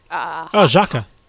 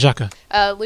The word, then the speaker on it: Xhaka: Caleb Thomas, hey, thoughts on, I'm going to butcher this, Xhaka. – Yeah. – Granit Xhaka.